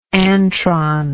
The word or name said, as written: antron